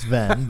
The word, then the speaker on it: Venn: I genuinely believe that we've reached a point where, like, it's like the Sven diagram. – Mm-hmm. – Am I saying that right? – Mm-hmm. Yep. – I don't know that it's Sven. – Yeah, it's not Sven. It is Ven, but yeah.